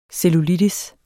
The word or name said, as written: cellulitis